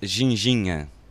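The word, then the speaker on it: ginjinha: This drink is called ginginha. – Ginginha? – The drink ginginha, exactly like you pronounced it.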